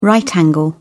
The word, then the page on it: right angle: British English